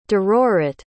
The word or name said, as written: derorit